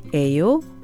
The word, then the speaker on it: 예요: The five question words 누구, 뭐, 어디, 언제, and 얼마 are followed by the verb be 예요, which is pronounced 에요 with a rising intonation.